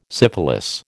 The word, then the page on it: syphilis: American English